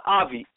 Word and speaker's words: Avi: Avi, A-V-I.